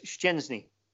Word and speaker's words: Szczęsny: and his name is Szczęsny. You know, there's a two sounds at the first couple of syllables, and then there's an N in there, a soft N in Polish.